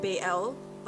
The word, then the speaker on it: Baal: So it's either pronounced Baal or Baal, but I'm going to say Baal.